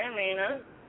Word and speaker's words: Amina: Hey, I'm here, and it's Amina. I'm sorry, it's misspelled with my Facebook, but it's Amina. – Amina? – Yeah, Amina with an A. – Hi, Amina.